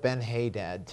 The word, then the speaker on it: Ben-Hadad: Now, if you'll remember, when chapter 20 began, the king of Syria, Ben-Hadad, we're going to go with Ben-Hadad tonight, amen? – Amen? – And that's how we'll pronounce it. You all convinced me of that. It's actually the right thing, right way to say it, I believe.